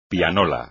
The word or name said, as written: pianola